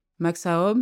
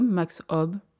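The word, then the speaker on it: Max Aub: Max Aoub, Max Aoub, Max Aoub. Autant de prononciations, allemandes, françaises ou encore espagnoles, que d'origine, d'identité, de personnage, pourrait-on dire.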